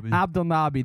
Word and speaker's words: Abdelnaby: Abdelnaby.